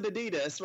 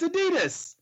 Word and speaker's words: Adidas: It's Adidas. – It's not. It's a German family name. That's how they pronounce it.